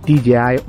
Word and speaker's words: DJI: Y esa es la pronunciación correcta. DJI Osmo Action.